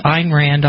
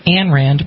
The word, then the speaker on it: Ayn Rand: The author is Ayn Rand, often incorrectly pronounced Ayn Rand, but it's A-Y-N. Last name is R-A-N-D.